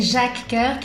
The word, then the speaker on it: Jacques Coeur: Sí, Jacques Coeur, que quiere decir Santiago Corazón.